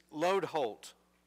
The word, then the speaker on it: Lodeholt: "It's Lodeholt," I said, trying to explain into the telephone the correct way to pronounce my last name. As you might expect, with a name like Lodeholt, over the years my name has been mispronounced more ways than I can remember. I've heard Hodeloat, Landholt, Lodebolt, even Leadhead, and my favorite, Hostelot.